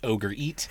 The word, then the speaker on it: orgeat: Yes, and for those of you who do not know what orgeat is, here in the East Bay, we pronounce that ogre eat.